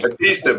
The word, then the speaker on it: Bautista: Batista, Batista.